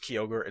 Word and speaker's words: Kyogre: I know some of you have heard this name being pronounced Kyogre. It's not pronounced Kyogre, it's pronounced Kyogre. There's a Y in the name. Another more commonly mispronounced name is Ho-Oh. It's spelled H-O-O-H.